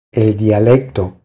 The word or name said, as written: El dialecto